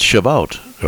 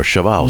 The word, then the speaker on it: Shavuot: It's Shavuot or Shavuos. Mm-hmm. There are a couple different pronunciations of it.